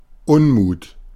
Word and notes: discontent: /ˌdɪskənˈtɛnt/

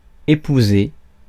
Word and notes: husband: /ˈhʌz.bənd/

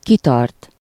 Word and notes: persister: France; [pɛʁ.sis.te]

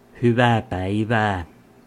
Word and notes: good day: American English

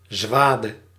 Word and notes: large: American English; /lɑɹdʒ/

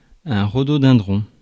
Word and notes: rhododendron: /ʁo.do.dɛ̃.dʁɔ̃/; France

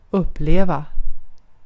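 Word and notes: uppleva: Swedish

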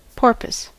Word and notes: porpoise: /ˈpɔː.pəs/; /ˈpɔːɹpəs/

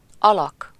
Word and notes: guy: /ˈɡaɪ/; [ɡaɪ]

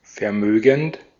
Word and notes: riche: France; [ʁiʃ]

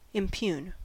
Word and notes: impugn: [ɪm.ˈpjuːn]

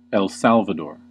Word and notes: El Salvador: /ɛl ˈsæl.və.dɔː/; /ɛl ˈsæl.və.dɔɹ/

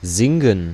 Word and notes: sing: /ˈsɪŋ/

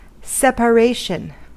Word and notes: separation: American English; [sɛ.pə.ˈreɪ.ʃən]

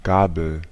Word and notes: fourchette: France; [fuʁ.ʃɛt]; [fɔʁ.ˈʃɛ.tə]

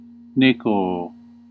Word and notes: check: /tʃɛk/; [tʃɛk]

